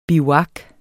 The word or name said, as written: bivuak